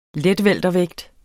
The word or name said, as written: letweltervægt